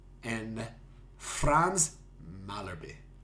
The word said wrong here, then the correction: Malherbe: Tell me what I butchered. – All of it. Tendai Tabariria. Oh my goodness, I can't say it either now. Bongi Mbanambi and Franz Malherbe.